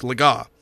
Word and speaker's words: Legas: You know, the announcer saying Cooper Legas or something like that, where he pronounced his name wrong because he hadn't gotten that far in the pronunciation guide. Cooper Legas or something like that. But, it's Legas.